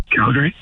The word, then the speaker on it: Calgary: I pronounce it Calgary. And I was like, am I in the wrong here? It really confused me a little bit. How do you say it? – Calgary. – Okay, great.